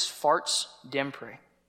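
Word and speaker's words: Fartsdemper: This is a Norwegian speed bump sign, and the correct, at least as far as I can tell, the correct pronunciation of the speed bump sign is Farts Dempre.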